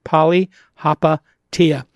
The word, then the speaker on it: Palihapitiya: With me, as always, my co host, Chamath Palihapitiya, if you don't know how to pronounce it, Poly Tia.